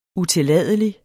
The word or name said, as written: utilladelig